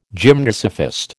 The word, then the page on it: gymnosophist: American English